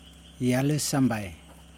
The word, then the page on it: jelisembai: Yokoim